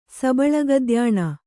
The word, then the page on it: ಸಬಳಗದ್ಯಾಣ: Kannada